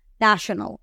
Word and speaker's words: national: Lo que hay que hacer es concentrarnos en la pronunciación de la T-I-O-N, En no vamos a decir nacional, ¿verdad? La T i O N, que les he enseñado varias veces, se dice en inglés shun. Entonces decimos national, national, national, ¿ok?